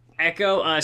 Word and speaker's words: EKOH: I like Futuristic, but saving how do you pronounce that? The other rapper there. Oh. E-K-O-H. – Echo. I think it would just be Echo, yeah. – Echo.